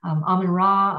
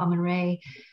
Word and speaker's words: Amun-Ra: It's pronounced, I've heard it pronounced both ways. I'm not sure if there's a correct way, but Amun-Ra, Amun-Re,